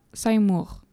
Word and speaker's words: Simurgh: tandis que Asmar chevauche l'oiseau multicolore Saïmour. Alors, il faut savoir que c'est écrit S-I-M-U-R-G-H, mais je fais confiance au film qui prononce Saïmour.